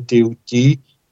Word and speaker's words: Thoth: Em egípcio se pronuncia Teuti, egípcio antigo, o deus Thoth, que é conhecido como Hermes Trismegisto, o Hermes três vezes grande.